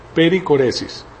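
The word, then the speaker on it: perichoresis: Entonces, ahora vamos a ver algo nuevo, que es la doctrina de la Trinidad y la pericoresis, pericoresis, con CH. Pericoresis se escribe peri, que es por arriba de, y coresis es C-H-O-R-E-S-I-S, viene del griego,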